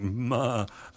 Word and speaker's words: Maher: And Frank Marr put out an album called Marvelous, spelled M-A-H-E-R, hence the sort of bleating in the pronunciation. But anyway, it's Frank Marr, and this is a medley of tunes from the album Marvelous.